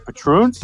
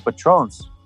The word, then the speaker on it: Patroons: And then that's how you say it, the Patroons? – The Patroons, yeah. – Wow. Yeah. I thought it was the Patrons.